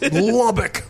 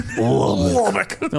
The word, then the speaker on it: Lubbock: Lubbock. – Lubbock. No, like, you have to say it like that.